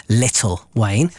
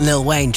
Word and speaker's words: Lil Wayne: I want to say Lil Wayne. – Lil Wayne, you know. And the other day, I did refer to him as Little Wayne. But in actual fact, it isn't Lil Wayne, just for Zara.